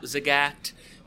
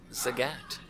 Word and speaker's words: Zagat: We bought Zagat. By the way, I learned this when I joined the infatuation. It's pronounced Zagat. – Yeah, it's Zagat.